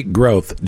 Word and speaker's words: Groth: Valerie Groth, G-R-O-T-H.